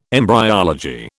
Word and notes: embryology: British English

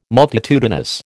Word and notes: multitudinous: American English

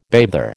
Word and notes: bather: American English

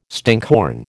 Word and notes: stinkhorn: American English

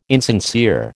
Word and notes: insincere: American English

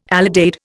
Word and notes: alidade: Indian

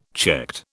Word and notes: checked: British English

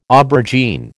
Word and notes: aubergine: American English

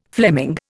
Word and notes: Fleming: Indian

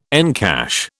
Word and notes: encash: British English

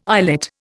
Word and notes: islet: Indian

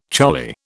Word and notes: choli: British English

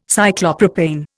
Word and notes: Cyclopropane: Indian

